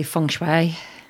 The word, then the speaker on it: Feng Shui: What I did is I wrote it phonetically and put F-U-N-G-S-W-A-Y so that I would pronounce it correctly. I know that's not how you spell it, but Feng Shui.